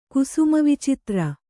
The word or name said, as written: ಕುಸುಮವಿಚಿತ್ರ